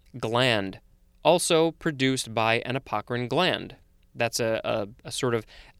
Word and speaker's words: apocrine: C-A-P-O-N. Next we have apocrine, could also be apocrine or apocrine. It is spelled A-P-O-C-R-I-N-E.